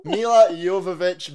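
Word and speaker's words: Milla Jovovich: Anderson, stars Mia Jovovich. I can never say her name properly. Honestly, I practiced that so much. Mila Jovovich, Michelle Rodriguez, and James Purefoy.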